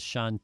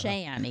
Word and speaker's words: Sean: He didn't even know who Sean Penn was. Right. Couldn't pronounce his name. Uh-huh. Shan, he called him.